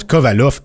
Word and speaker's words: Kovalev: Kovalev, ça se prononce Kovalov. EV en, russe, c'est off. – Fait que c'est Kovalov.